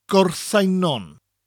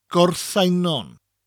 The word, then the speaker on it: Gorseinon: It's called Gorseinon. In Welsh, it's pronounced Gorseinon. Gorseinon.